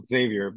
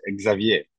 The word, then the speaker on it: Xavier: yeah, in, in English is Xavier, but in in in French, for example, is Xavier. So ah, it's, it's, Xavier. It's another accent.